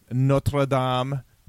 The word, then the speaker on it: Notre Dame: But it's Alabama, Clemson, Notre Dame, and Oklahoma. – Thank you for pronouncing Notre Dame in the Irish way.